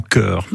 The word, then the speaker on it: Ker: Et c'est là où j'ai rencontré Marie-Louise Fay, qui est responsable de l'association Cœur. Alors Cœur, ça ne s'écrit pas C-O-E-U-R, mais K-E-R,